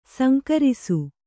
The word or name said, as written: ಸಂಘರ್ಷ